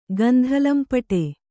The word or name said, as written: ಗಂಧಲಂಪಟೆ